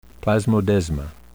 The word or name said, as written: plasmodesma